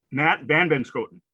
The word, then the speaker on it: Matt Vanbenschoten: Is that, I'm saying it right, Benschoten, or is it Beschoten? – Matt Vanbenschoten.